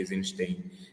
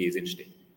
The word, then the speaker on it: Eisenstein: mostrar todas essas facetas do Sergei Eisenstein. – Bom, você já a, a, pronúncia correta agora vocês já sabem é a, não é a minha.